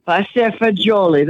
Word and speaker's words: pasta e fagioli: Well, we're going to do it's pasta e fagioli. Did I say that right? – Pasta fagioli. That's right. You did.